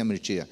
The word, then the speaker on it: Mircea: Escreve Mircea, mas pronuncia Mirtia.